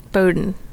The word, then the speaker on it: Bowdoin: Bowdoin.